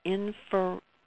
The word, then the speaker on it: interferometry: I'm probably going to mangle the pronunciation, but it's angle-resolved low-coherence interferometry? – Interferometry, yeah.